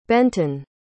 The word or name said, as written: Benton